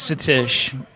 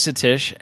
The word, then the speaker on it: Satish: So my real name is Satish. My real name is Satish, S-A-T-I-S-H, kind of phonetically like Catfish.